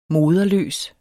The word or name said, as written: moderløs